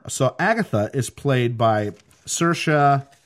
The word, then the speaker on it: Saoirse: So it's Saoirse.